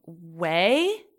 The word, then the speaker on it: Ouai: Right now I'm using, Oribe, Way. I think that's how you say it. It's like O-U-A-I.